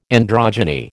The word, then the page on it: androgyny: American English